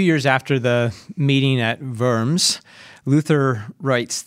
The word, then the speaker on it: Worms: This last week, we celebrated the 500th anniversary of the, now it looks like Diet of Worms, but that's not how we say it, right? How do we say it? – The Diet of Worms, but Worms.